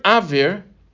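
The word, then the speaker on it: avir (אויר): The word avir has in it the letters aleph, vav, and resh, or, and it has a silent letter inside of it. The letter yud. The letter yud, which is a silent letter.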